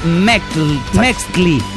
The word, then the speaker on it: Metzli: Metzli, Luna, se pronuncia Mextli.